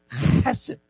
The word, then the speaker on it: hesed: In the Old Testament, the Hebrew word is hesed, but if you say it like in the original language, you gotta crack down hard on the H. It's really a fun word. It's hesed.